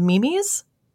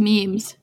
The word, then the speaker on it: Mimi: Someone from Australia in the Reddit thread mentioned something from the folklore of indigenous people of Northern Australia, and they're called Mimis. which is a very cute little name. And they are... – It's pronounced memes. – Oh my God. M-I-M-I.